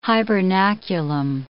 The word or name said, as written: hibernaculum